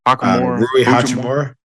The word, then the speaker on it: Hachimura: Did they start Hachimura? I can never say motherfucker's name. Is that how you say his name? – Hachimura.